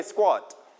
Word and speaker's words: squat: Everybody repeat after me, squat. Okay, squat. In English, we pronounce k as k when it is with s.